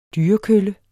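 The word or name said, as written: dyrekølle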